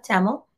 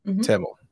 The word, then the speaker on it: Tamil: Did I say that right? – Tamil. – Tamil. As a Sri Lankan Tamil woman.